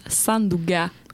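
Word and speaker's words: Sandunga: Et également, une chanson, nous allons écouter la Sanduka, si j'ai bien prononcé, Milly Brosseau-Gaultier, à l'espagnol. – Ben, ça serait plus Sanduga.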